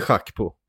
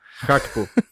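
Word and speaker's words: Gakpo: Cody Schack på. Som det egentligen ska uttalas. – Nej men sluta. – Schack på. Han är holländare. Det ska vara Schack på.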